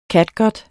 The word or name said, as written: catgut